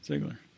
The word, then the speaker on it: Ziegler: I'm like the John Ziegler Am I pronouncing, is it Ziegler or Ziegler? – Ziegler.